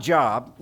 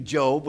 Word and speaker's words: Job: It's not Job, looks like Job, but it's actually Job with a long O.